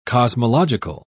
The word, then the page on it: cosmological: British English